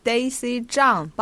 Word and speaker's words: Zhang: Daisy Jan. Did I pronounce that correctly? – Daisy Zhang, but I think you pronounce pen fantastic.